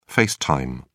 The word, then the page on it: face time: British English